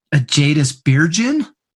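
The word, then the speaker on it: Ágætis byrjun: They have this album called Ajadus Byrgin, something like that. – And yep, that's how you pronounce it.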